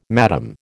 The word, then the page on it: madam: American English